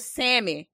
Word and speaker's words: salmon: So your girl didn't even know what real salmon looked like. And, you know, it ain't been too long that I took the L out of my pronunciation of salmon, because I was a salmon girl.